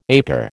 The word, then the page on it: acre: American English